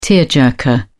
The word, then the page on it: tearjerker: British English